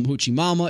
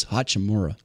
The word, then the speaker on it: Hachimura: All right, you see Kyrie's back with Dallas, Middleton's back with the Bucks, Rui Hachimura, don't call him Hoochie Mama, is back with the Lakers. That's what some of the hosts call him, Hoochie Mama. It's Hachimura. Get his name right.